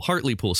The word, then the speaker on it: Hartlepool: Hartlepool, Hartlepool. Spelled in America, we would pronounce it Hartlepool. H-A-R-T-L-E-P-O-O-L.